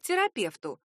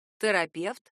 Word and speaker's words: терапевт: Свобода выбора предоставляется и тем, кто приходит на прием к терапевту. Это слово можно произносить и твердо, и мягко. Терапевт и терапевт.